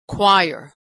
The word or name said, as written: choir